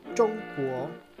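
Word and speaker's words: Chongguo (中国): que se pronuncia Chongguo.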